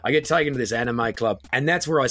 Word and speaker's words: anime: And I'd never heard it pronounced. I'd only ever seen it written down. So I was going, anime? You mean anime?